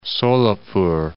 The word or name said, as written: Solapur